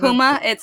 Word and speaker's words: Huma: How do you pronounce it? – Huma. Huma is a gel. It's a company, an American gel company.